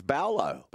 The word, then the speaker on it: Paulo: So there's big Junior Paulo, but I'm told now that it's not Baolo. Junior Paulo. – Baolo, is it? – Baolo. Apparently it's Baolo.